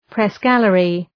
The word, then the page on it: press gallery: pres’gælərı